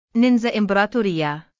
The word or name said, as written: NINXA IMPRATORIA